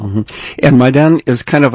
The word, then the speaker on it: Maidan: Maidan. – Maidan.